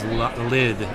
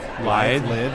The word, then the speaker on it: lithe: He was lithe. Is that the right way to say it? – Lithe. – Lithe. L-I-T-H-E.